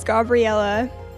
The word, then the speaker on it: Gabriella: So my name is Gabriella, which is, the pronunciation is Italian.